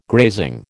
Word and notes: grazing: American English

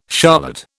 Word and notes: charlotte: British English

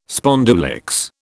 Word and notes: spondulicks: British English

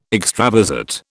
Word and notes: extravasate: British English

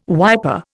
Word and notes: wiper: Indian

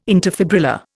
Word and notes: interfibrillar: Indian

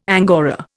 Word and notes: angora: Indian

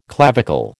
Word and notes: clavicle: American English

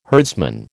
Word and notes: herdsman: American English

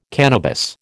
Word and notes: cannabis: American English

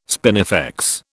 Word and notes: spinifex: British English

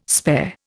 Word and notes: spare: Indian

